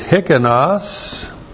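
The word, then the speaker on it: hikanos (ἱκανός): And the adjective is hikanos, H-I-K-A-N-O-S. And there is a noun that comes from that verb as well, hikanotes. H-I-K-A-N-O-T.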